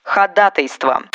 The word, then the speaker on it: ходатайство: За слово «вручит» вам наград никто не вручит, потому что так говорить неправильно ходатайство. Вариант ходатайства встречается в речи, возможно, чаще, чем правильная постановка ударения. Но словарю известен лишь один правильный вариант с ударением на второй слог.